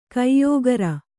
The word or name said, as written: ಕೊಯ್ಗುರಿ